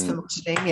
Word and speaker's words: Famotidine: Famotidine. – Yes. Famotidine.